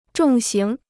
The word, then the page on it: 重型: zhòng xíng